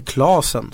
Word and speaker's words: Klasén: Det var då att eh, eh, Lugano-proffset eh, Linus Klasén, eller Klasen ska man uttala efternamnet, inte är med i eh, truppen.